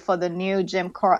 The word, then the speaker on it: Jim Crow: Yeah, um, like Christian, I knew about segregation, but the Jim Crow, did I say that right? Jim Crow yeah, law Jim Crow.